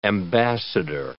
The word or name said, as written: ambassador